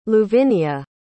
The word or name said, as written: luvinia